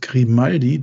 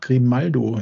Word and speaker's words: Grimaldo: Und zwar wird Grimaldi, der immer noch Grimaldo immer wieder genannt wird. Ich weiß nicht warum. Also das gibt immer, wir haben immer irgendeinen Spieler, der jedes Mal falsch ausgesprochen wird.